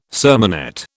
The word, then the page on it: sermonette: British English